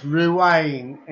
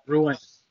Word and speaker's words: Ruane: Now, did I say your name right, John? Uh, no, it's Ruane. It's uh, Ruane. It's an it's an Irish name.